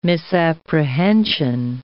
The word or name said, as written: misapprehension